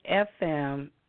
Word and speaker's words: athame: With a consecrated athame, and it's spelled A-T-H-A-M-E, cut out a heart-shaped piece from the peel of a lemon.